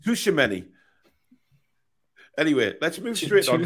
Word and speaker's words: Tchouaméni: Tchouaméni. Yeah, silent T.